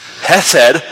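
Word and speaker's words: hesed (חסד): hesed. Hesed. You got to say it like that. It's kind of the guttural sound.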